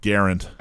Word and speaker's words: Garand: So you can get Grands, you can get M1 carbines, you can get 1911s. Oh, yeah. Actually, it's right. Garand. – Garand. – Actually, it's pronounced Garand. So I've been told.